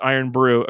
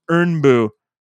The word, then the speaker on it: Irn-Bru: Oh, it's not pronounced Iron Brew. According to Katie, it's pronounced Urn Boo.